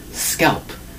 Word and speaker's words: scalp: S-C-A-L-P. S-C-A-L-P, scalp, one syllable for the word scalp. Notice how we say that, scalp. We have to hear the P at the end. That's the key.